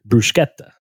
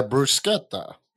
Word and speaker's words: bruschetta: In Italian, you don't pronounce the H. It's so like bruschetta is actually pronounced bruschetta. – Yeah, bruschetta.